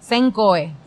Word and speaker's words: Senko: Se escribe S-E-N-K-O. Senkoe.